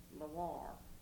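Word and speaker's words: LaWarre: My maiden name is That'd be Loire. – And how do you spell that? – You spell it capital L-A, capital W-A-R-R-E. It's kind of French-like.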